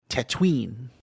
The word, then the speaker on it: Tatooine: The Book of Boba Fett, Chapter 2, The Trials of Tatooine, if you want to say it like Temuera Morrison. Tatooine. – Tatooine? – So Chapter 2, Trials of Tatooine.